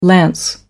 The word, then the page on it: lance: American English